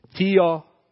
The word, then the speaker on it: theopneustos: But what it means theopneustos.